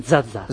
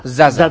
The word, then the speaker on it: Zaza: Es un delantero, a mí me parece más para ¿cómo se dice? ¿Zaza? ¿Sasa? – Yo, en Italia dicen Zaza. – Zaza.